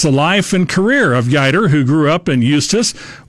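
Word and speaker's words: Yeutter: Trade Representative and Secretary of Agriculture Clayton Yeider is being released. Rhymes with fighter, Clayton Yeider, American Statesman is the name of it.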